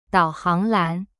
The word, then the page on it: 导航栏: dao hang lan